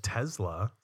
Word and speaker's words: Tesla: I recently purchased a Tesla Model 3. And you pronounced it correctly. I'm very proud of you.